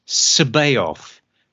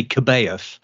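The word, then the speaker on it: C'baoth: But he pronounced it Joris Sabaoth. And I said, no, it's obviously Kabaoth.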